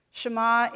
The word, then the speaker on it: Shema: This is the Hebrew word. It's pronounced Shema, and it is the first word, which is hear. Hear, O Israel, the Lord your God is one.